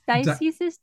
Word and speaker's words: dioceses: Dioceses? Dioceses? – Yeah, dioceses is how you say the plural of diocese.